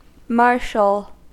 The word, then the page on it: marshal: American English